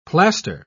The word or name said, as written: plaster